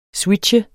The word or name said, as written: SWITCHER